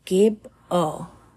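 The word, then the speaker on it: gebo: So the phonetic value is G, pronunciation Gib O. Summary is gift, gratitude, exchange, receiving through sacrifice or offerings. Gabo is a gift or an exchange.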